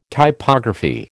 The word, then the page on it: Typography: American English